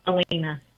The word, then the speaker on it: Helena: Helena, is it pronounced Helena or Helena? – Helena. – Helena, that sounds classier, doesn't it? Helena. – Just like the saint.